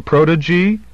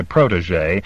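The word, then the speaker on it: protégé: "Wasn't Giles DeVore your protege?" He pronounced the last word with an English soft G and with a long E at the end.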